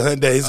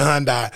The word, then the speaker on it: Hyundai: Some people pronounce it Hyundai. Some people pronounce it Hyundai.